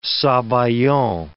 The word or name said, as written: sabayon